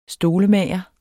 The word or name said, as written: stolemager